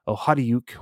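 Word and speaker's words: Okhotiuk: The Washington Capitals lucked out, and they're able to tie the game when a rebound bounced off of Nikita Ohadiuk. We're going to say it like that because that's what I believe that's what Nick Nolenberger reported as Nikita telling him that's how you say it.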